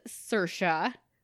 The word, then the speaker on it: Saoirse: Her name is Saoirse Ronan.